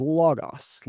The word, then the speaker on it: logos: So the original language in Greek, this word is the word logos. Logos, logos. People, I've heard it pronounced both ways.